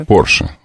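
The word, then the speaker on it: Porsche: Порше, ударение все-таки, по-немецки ставим.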